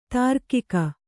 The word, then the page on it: ದೀರ್ಘಿಕೆ: dīrghike; Kannada